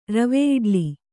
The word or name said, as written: ರವೆಯಿಡ್ಲಿ